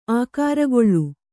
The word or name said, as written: ಆಕಾರಗೊಳ್ಳು